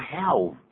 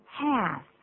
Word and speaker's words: half: 小心呢，它这边有个 l，能不能念成 half？ – 不行，l 不发音的，念作 half。 Half，所以 where's 大家可以看到是 where is 的缩写，这种缩写性是经常在绘画里头出现的啊。